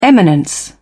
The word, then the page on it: eminence: British English